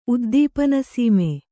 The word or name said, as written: ಉದ್ದೀಪನಸೀಮೆ